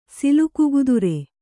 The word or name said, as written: ಸಿಲ್ಕುಗುದುರೆ